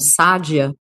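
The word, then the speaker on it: Sadia: A gente sabe, você comentou que lá ela é pronunciada como Sádia, né?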